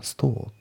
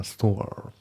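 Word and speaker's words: store: で、あと最後のストアなんですけど、これイギリス英語だからストアって感じですね。アメリカ英語だったらストアって感じで R がちゃんとね発音されるんですけど、イギリス英語の場合こういう感じでねストアって感じになるんですよね。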